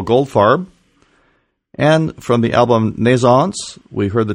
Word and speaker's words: Naissance: I don't say it correctly. Let me hear you say it one more time. – Naissance. – Naissance. Okay. – Naissance. Great.